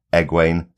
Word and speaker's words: Egwene: On the show, she's being called Egwene, so that is what we have to try and stick with.